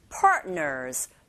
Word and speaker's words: partners: 뒤에 나오는 th는 the, 여기서 the, worthy라고 발음하시면 되고, partner라가 아니라 partners, partners, 아 부분에 강세 주시면 됩니다.